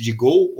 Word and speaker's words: DGO: Ela é, é dona, né, controladora do serviço de Go ou DGO, né, para quem não sabe como é que pronuncia, eu até pouco tempo atrás falava errado também, tá? Então é de Go que se fala, antiga DirecTV Go, né?